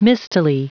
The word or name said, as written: mistily